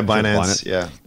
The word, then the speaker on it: Binance: Is it Binance? Is that how they pronounce this thing? – Yeah, Binance.